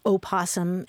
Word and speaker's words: opossum: P-O-S-S-U-M. But so it's not that the O is silent, it's really that there are two different versions of that word, opossum and possum.